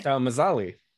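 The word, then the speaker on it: Mzali: It talks about them being in Ustalav, talks like them being in, I'm going to butcher this, MZA. – Mazali. – Mazali. Yeah, okay. – That's the city where the Bright Lions operate in the Mwangi Expanse. – Right. I can never pronounce it. There's like a hidden vowel there.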